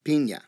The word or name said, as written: pina